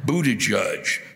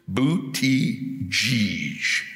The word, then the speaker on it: Buttigieg: you still can't fucking say his name right. Buttigieg, Buttigieg, Buttigieg. God damn it, it's Buttigieg.